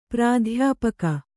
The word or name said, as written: ಪ್ರಾಧ್ಯಾಪಕ